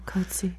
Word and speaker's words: Coetzee: Coetzee. Is that how you pronounce his name? – Yeah, Coetzee.